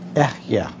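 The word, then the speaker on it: Ehyah (אֶהְיֶה): When God says, I am, he uses a Hebrew word which is pronounced something like this, Ehyah.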